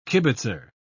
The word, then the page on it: kibitzer: British English